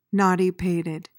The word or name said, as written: knotty-pated